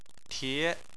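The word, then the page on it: tie: tone 2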